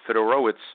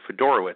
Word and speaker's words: Fedorowicz: Fedorowicz, or actually Fedorowicz, I guess now it's pronounced. I thought it was pronounced differently.